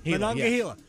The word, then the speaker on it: Monongahela: I always trouble pronounce this. Monongahela? – Gila. Monongahela. Yes.